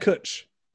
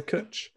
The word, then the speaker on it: cwtch: Have a Kutch. You have a Kutch. – But it's spelled C-W-T-C-H. Yeah. – So a W in Welsh is a vowel. – Yeah, it's so two U's.